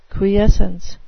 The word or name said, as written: quiescence